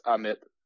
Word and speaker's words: Ammit: The first M is silent. It's Ammit.